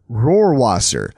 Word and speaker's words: Rohrwasser: Rohrwasser, like spelled like you would expect it to be the Luftwaffen,